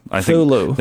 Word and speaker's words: Cthulhu: Or some people say just Thulu. – Thulu. – They say it's like Sulu, but with a th sound.